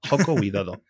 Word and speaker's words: Joko Widodo: Yoko Guidodo, espero haberlo pronunciado bien, Yoko, es un nombre guay, tío, Yoko. Sí, la verdad es como, que Como, como Yoko pero con J, Joko. Joko Widodo.